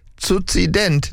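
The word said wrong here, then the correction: Kukident: Oh, Frieda, das schreibt man mit K, das heißt Cookie-Dent.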